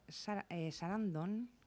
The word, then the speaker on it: Sarandon: Y bueno, la Pena de Muerte, la historia narra la relación entre Helen Presham, interpretada por Susan Sarandon, que se Sarandon.